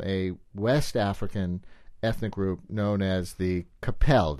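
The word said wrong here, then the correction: Kpelle: It's the Pele. The K is silent. It's K-P-E-L-L-E, and they're from Liberia.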